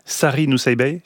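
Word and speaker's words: Sari Nusseibeh: Sari Nousseibeh, c'est comme ça qu'on prononce? – Oui. – C'est parce que je l'ai lu, mais je ne sais pas prononcer.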